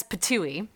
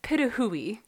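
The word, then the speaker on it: pitohui: So there's one kind of bird called the hooded pitahui, which it's spelled as patui, and it's not pronounced that way. And it positively breaks my heart, but it's the pitahui.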